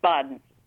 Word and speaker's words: Bawden: Yeah, it's Bawden.